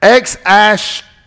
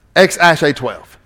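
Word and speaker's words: X Æ A-12: You're going to you pronounce it X-Ash-A-12. X-Ash-A-12.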